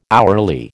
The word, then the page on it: hourly: American English